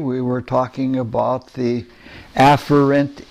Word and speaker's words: afferent: By the way, I looked it up on Webster where they pronounce the words for you, and the afferent is the proper pronunciation. Efferent has two pronunciations, but in both of them, the focus should be on the first syllable.